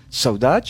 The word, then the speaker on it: saudade: Et alors, saudade, tu m'as dit hors antenne que ça venait du mot portugais saudade, prononcé plutôt à la brésilienne.